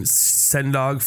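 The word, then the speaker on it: Sen Dog: who it's a metal band featuring Sendog from Cypress Hill.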